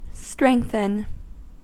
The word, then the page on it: strengthen: American English